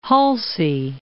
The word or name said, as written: Halsey